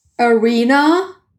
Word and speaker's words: Irina: I've changed to Irina. Like a concert arena, because everyone says it differently.